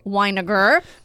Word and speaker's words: Winegar: Mr. Bridger Winegar.